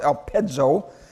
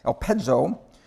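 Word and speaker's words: elpezo (ἐλπίζω): As you look at your sheet there, you can see hope, uh, elpizo in the Greek is pronounced elpezo, elpezo. It's used over 100 times in the New Testament.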